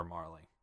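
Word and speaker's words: Marle: Oh, apparently in Japan, her name is M-A-R-L, so it's supposed to be Marl.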